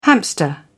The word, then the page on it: hamster: British English